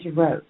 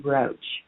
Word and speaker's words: Roach: My middle name is Roach. If we were in France, they would pronounce it Rocher. But here in the U.S., we say Roach, just like the bug.